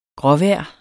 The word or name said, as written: gråvejr